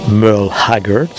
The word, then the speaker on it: Merle Haggard: Donc en anglais on le prononce Merle Haggard.